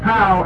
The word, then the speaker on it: pau: It says, this trip is pow, P-A-U. That's pronounced pow, and it means ended, over.